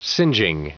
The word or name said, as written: singeing